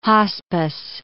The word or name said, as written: hospice